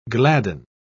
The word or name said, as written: gladden